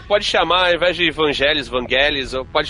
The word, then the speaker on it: Vangelis: É Evangelis? – Não é Evangelis. – Não é, não é do Evangelis. – Ah, cada um pronuncia de um jeito, O, o, o, o Ridley Scott fala Vangelis.